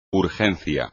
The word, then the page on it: urgencia: espagnol